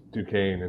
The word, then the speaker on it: Duquesne: I didn't know how to pronounce Duquesne until you guys said it. There's an S in there. I guess it's silent.